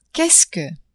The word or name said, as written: qu'est-ce que